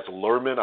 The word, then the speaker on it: Luhrmann: The director of The Get Down is Baz Luhrmann, I believe is how you pronounce his name.